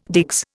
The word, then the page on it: digs: Indian